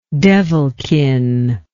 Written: devilkin

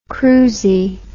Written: cruisey

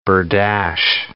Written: berdache

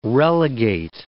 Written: relegate

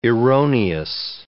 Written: erroneous

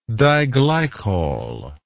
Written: diglycol

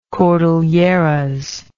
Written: cordilleras

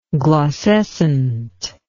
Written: glaucescence

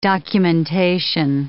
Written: documentation

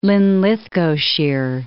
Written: Linlithgowshire

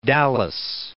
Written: Dallas

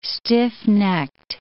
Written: stiff-necked

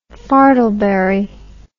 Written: fartleberry

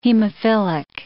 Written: hemophilic

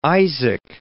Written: Isaac

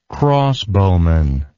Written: crossbowman

